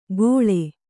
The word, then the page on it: ಗೋಳೆ: gōḷe